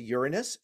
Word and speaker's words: Uranus: You'd have to more than double it all over again to get to Uranus. And for my kids who are listening, yes, that's the correct pronunciation.